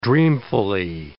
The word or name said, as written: dreamfully